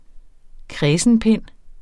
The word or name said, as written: kræsenpind